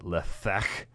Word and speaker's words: lethek: By the way, I think when you pronounce that lethek, you have to get a little phlegm there. You also have to spit just a little bit. Otherwise, it's doesn't count. You haven't said it correctly.